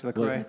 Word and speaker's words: LaCroix: Right now, I think, gosh, I'm looking at this LaCroix, right? Or how do you say it? LaCroix? – That's correct, LaCroix.